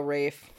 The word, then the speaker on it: Ralph: Oh, Rafe. Ralph, that's how they say it Rafe. In America.